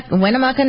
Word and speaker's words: Winnemucca: Winnemucca. – Is that it? – Yeah, Winnemucca? Yeah, Winnemucca, Nevada, with just a little under 8 inches average rainfall.